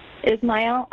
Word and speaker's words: Ismael: May, M-A-E. – And your last name, May? – Ismael, I-S-M-A-E-L. – I'm sorry, spell that for me again. – I-S-M-A-E-L.